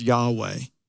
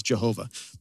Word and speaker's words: Yahweh: And let me just remind you, when you see the word Lord, all caps, the actual Hebrew word is Yahweh, sometimes mispronounced as Jehovah, but Yahweh in Hebrew.